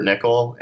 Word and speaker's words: Nickell: But Facebook, just you can look me up at Robert Nickel. And Facebook is crazy. I do a lot of business through Facebook, a lot of messaging, a lot of communication done there. So my last name is spelled N-I-C-K-E-L-L.